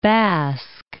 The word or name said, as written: Basque Country